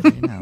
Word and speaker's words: Reno: Reno, yes. Reno. You said it right.